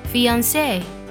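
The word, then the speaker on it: fiancée: 未婚妻的拼写是 f a n c w e，读作 fiance。而未婚夫呢可以读作 fiance，也可以读作 fiance，两个都对。不过呢，它会比未婚妻呢少一个字母 e。比如说这个句子。 The whole incident had upset me and my fiance terribly。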